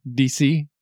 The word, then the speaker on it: Dixit: Dixit, or DC, if you're pronouncing it the French way. – DC? – But it's D-I-X-I-T, and it's a bit, it combines the ideas of like a Pictionary-ish style thing, but with apples to apples.